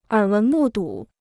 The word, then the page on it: 耳闻目睹: ěr wén mù dǔ